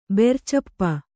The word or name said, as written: ಬೆರ್ಚಪ್ಪ